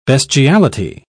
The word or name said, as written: bestiality